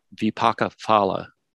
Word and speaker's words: vipaka phala: And it's getting conflated with another word, vipaka phala. Vipaka phala, I think that's the way it's supposed to be pronounced. The PH is a P, not a F sound.